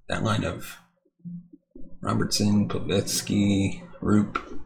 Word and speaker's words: Roope: I just realized I had been saying Rupert instead of Roop.